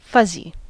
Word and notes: fuzzy: American English

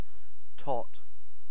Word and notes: tot: British English

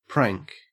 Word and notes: prank: Australian English

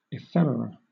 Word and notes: ephemera: British English

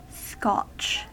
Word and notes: scotch: American English

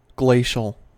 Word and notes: glacial: American English

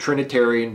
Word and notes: trinitarian: American English

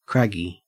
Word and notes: craggy: Australian English

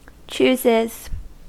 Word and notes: chooses: American English

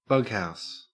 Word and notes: bughouse: Australian English

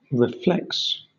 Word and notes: reflex: British English

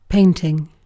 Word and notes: painting: British English

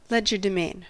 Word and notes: legerdemain: American English

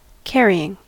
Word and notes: carrying: American English

